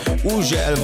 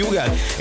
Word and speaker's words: Hugel: Így van, az úriember, akinek minden országban másképp ejtik a nevét. Van, ahol Ugel, van, ahol Hügel, van, ahol Ugel, meg mindenféle kép, de nálunk úgy mondjuk, hogy Hugel, egy Salto és egy Jamaican Bam Bam érkezik, a csavarjátok fel a hangerőt.